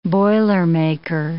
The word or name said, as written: boilermaker